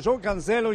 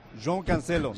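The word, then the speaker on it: João Cancelo: Para el impulso de Joao Cancelo, qué buen balón, qué bonito, de 40 metros de Joao Cancelo y qué control de Joao Félix.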